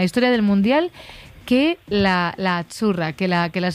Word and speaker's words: Azzurra: La Z no es la Z como decimos en catalán tan sonora, no. Ah, es Azzurra. – Muy bonito, muy bonito.